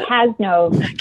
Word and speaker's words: Kassnove: I know you just told me, but Kassnove. It's a name that was made up at Ellis Island. So it's basically however you want to say.